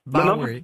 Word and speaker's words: Bowery: Bowery.